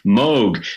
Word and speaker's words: Moog: And he said, well, we can use the original, you know, German-Dutch pronunciation. Moog.